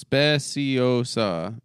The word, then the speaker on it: Speciosa: Speciosa, pronounced Speciosa, and Kratom, pronounced Kratom.